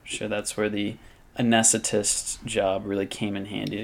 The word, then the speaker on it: anesthetist: And she helped support John through nursing school and while earning a license as an anesthetist? It's not an anesthesiologist, but it's like a nurse anesthetist. I don't know. – My uncle does that. Oh. And that is how you say it.